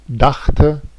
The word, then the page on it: dachte: /ˈdax.tə/